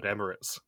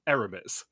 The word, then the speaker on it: Emirates: And in the continuation of my favorite running editor's joke for the entire season, Joseph and Grace leave the pit start, and Grace cannot pronounce the word Emirates. She instead says Eremis.